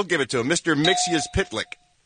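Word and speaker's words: Mxyzptlk: It's Mixius Pitlick.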